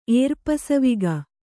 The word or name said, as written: ಏರ್ಪಸವಿಗ